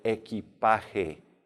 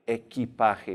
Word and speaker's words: equipaje: And your luggage in general is going to be el equipaje. Equipaje, remember that Q, U sounds like a K.